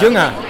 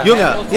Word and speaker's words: Jünger: Jünger. Am I still saying it right? That's how you say it, right? – Jünger. Yeah. – Jünger. Yeah. Yeah.